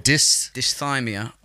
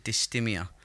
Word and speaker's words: dysthymia: Dysthymia. Sometimes people pronounce it dysthymia.